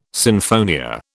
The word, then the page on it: sinfonia: British English